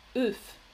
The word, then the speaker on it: œuf: On dit un œuf. Un œuf.